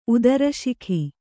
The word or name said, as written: ಉದರಶಿಖಿ